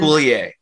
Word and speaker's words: Coulier: And Coulier is apparently how it's pronounced.